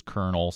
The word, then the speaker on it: colonel: We've mentioned a number of them already, but one that I have absolutely always wondered about is colonel, C-O-L-O-N-E-L. Can you explain that one? That one makes no sense to me at all. Why it's an R sound, but we spell it colonel.